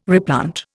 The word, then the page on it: replant: Indian